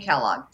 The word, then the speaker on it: Kellog: So the nice thing is I am on just about all social media as Tiffany Kellogg, T-I-F-F-A-N-I-E, Kellogg, K-E-L-L-O-G.